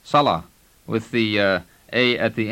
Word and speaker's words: صلاة: Prayer in Arabic is صلاة. – Listen to it and notice how the word ends in the long vowel sound ah.